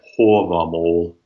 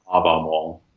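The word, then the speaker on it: Hávamál: where the long A is rounded, hence why I say hovamol, not havamol.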